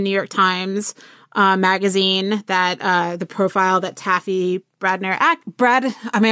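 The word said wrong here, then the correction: Brodesser-Akner: Ackner, right? – Thank you. – She's wonderful. She wrote a piece about Margot Kidder that made me sob over the weekend. – Taffy Brodesser Ackner.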